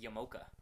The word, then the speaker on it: yarmulke: It's like yarmulke. Have you ever seen the spelling of yarmulke? – Yes. – It's like yamoka, isn't it?